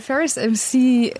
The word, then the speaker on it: Ferris MC: Und habe den Ferris MC, spricht man den überhaupt so? Ja, ne? – Ja.